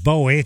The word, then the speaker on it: Bowie: We're trying to discover how to pronounce David Bowie, like arrow, bow and arrow. David Bowie.